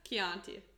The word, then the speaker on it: Chianti: That's Chianti. – My red wine. Chianti. Did I say Chianti? – Yeah. – What is it? – Chianti.